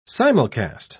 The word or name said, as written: simulcast